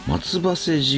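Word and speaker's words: 松橋: そして 1985 年に起こった松葉瀬事件、松の橋と書いて松葉瀬と読むんですけれども、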